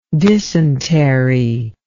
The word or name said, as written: dysentery